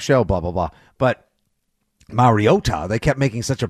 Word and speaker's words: Mariota: Like you said, number one, you can hold down even after last night's, oh God, I've never wanted Marcus Mariota to complete, Mariota.